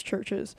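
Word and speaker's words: CHVRCHES: Churches, which is not spelled how you think it is, it's C-H-V-R-C-H-E-S, which I've heard people pronounce it Churches, and it's not, it's Churches.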